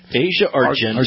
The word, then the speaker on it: Argento: I don't know how to say it. – Asia Argento. – Argento, yeah.